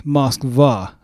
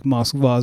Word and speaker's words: Moskva: It looks like almost M-O-C-K-B-A. And the reason why it sounds like Moskva is because that B, what looks like a capital B in Cyrillic and in Russian, that's pronounced V as in Victor. It's pronounced V in Russian. And so that's why you get that Moskva. That's how you get that sound.